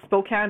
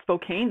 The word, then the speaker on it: Spokane: Also, it's pronounced Spokane, not Spokane. They had to teach me that.